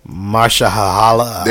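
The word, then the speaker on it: Mahershala: Oh, hey, was it True Detective Season 3 just started with Marsha Ali? – Oh, yeah. – But that's not how you say his name. – Marsha Hahala. – There we go.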